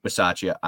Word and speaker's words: Bisaccia: was Buciaglia, if I said his name right. But isn't it like Bisaccia? – Bisaccia.